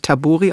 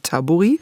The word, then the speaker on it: Tabori: In der Vorbereitung auf das Hörspiel habe ich so gemerkt, huch, wird vielleicht wirklich gar nicht Tabori ausgesprochen, sondern Tabori. Wahrscheinlich finno-ugrisch, Betonung erste Silbe.